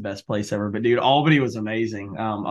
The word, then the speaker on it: Albany: Well, well you pronounce it Albany. It's it's two syllables there.